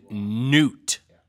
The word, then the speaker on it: Knute: Gipp and Newt. Who would name their kid Newt? With, by the way, a silent K.